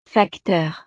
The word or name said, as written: facteur